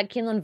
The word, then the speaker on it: Quinlan: The name drop of uh, uh you'll probably know how to actually say his name is, uh, I think uh, Quinlan um, Vos. – I think it's uh, Kinlan Vos. Kinlan Vos, yeah.